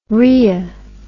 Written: rrhea

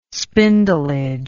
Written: spindleage

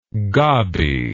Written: gobby

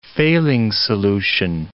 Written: Fehling's solution

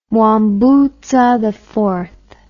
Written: mwambutsaiv

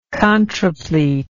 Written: contraplete

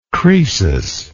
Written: croesus

